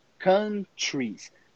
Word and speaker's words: countries: Aquele OU, a gente nunca vai pronunciar aquele U. Country, não. Countries. Se for singular, country. Se for plural, countries. Countries.